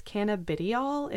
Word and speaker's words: cannabidiol: So CBD is actually I always mess up how you say it. It's cannabidiol is what it's called.